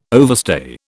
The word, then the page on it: overstay: British English